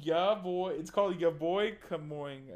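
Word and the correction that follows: Kongming: I think that's, I'm saying it correctly. – Yeah, boy, come on. – Kong Ming. Ya boy Kong Ming.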